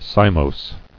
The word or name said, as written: Cymous